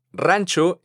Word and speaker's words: ranch: La primera de ellas es ranch, que como seguramente puedes escuchar, viene de la palabra rancho en español. Vamos a ver la diferencia en la pronunciación de estas dos palabras. Rancho, ranch. Aquí tenemos dos sonidos a los cuales hay que prestar atención.